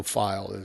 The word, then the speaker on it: Feil: It's Michael Feil is how it's pronounced. I had to ask him, you know, because it's spelled F-E-I-L. And I'm like, how do you pronounce this one?